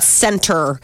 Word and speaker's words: Centr: Centr.